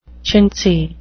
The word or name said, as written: chintzy